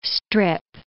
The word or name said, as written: strip